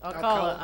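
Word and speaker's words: Alcalá: So this episode was directed by Felix Alcala. Is that how I pronounce it? – Alcala. – I'm sorry. Sorry, Felix. It's Felix Alcala.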